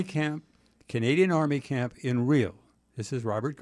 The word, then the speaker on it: Rhyl: But it's pronounced Rhyl. That part I was instructed on how to say it.